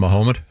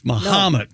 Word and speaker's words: Mahomet: The H-O is pronounced like ha, not like ho. – So it's not Mahomet? – It's Mahomet.